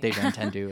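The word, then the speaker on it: Déjà Entendu: Probably Deja Entendu because it's French title, but no, Deja Entendu is how people pronounce it.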